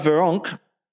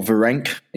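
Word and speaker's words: Virenque: It's Richard Veronque, or Verenc, if you're pronouncing it in English really badly.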